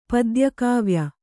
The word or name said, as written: ಪದ್ಯಕಾವ್ಯ